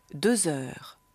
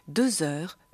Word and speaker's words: deux heures: Quelle heure est-il il est deux heures. Bei deux heures wird gebunden. Deux heures. Dagegen muss es heißen deux francs. Il est trois heures. Aber trois francs.